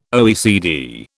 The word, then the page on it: OECD: British English